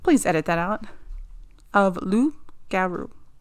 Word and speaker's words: loup-garou: In French folklore, the werewolf is called the loup-garou. Did I say that? – Loup-garou? Yeah.